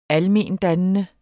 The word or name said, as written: almendannende